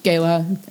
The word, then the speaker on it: Gala: Let's say Gala, like the apple. – Gala. It's just G-A-L-A.